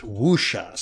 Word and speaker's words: wuxia: It's spelled wuxia, but because it's Chinese, it's pronounced wuxia.